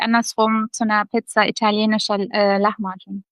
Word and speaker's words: Lahmacun: Wie spricht man das richtig aus? – Lahmacun. – Lahmacun.